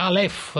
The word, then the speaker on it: Aleph: E noi andiamo invece in una libreria milanese che ha un nome bizzarro, che come si pronuncia? Aleph, Aleph, è così? – Sì.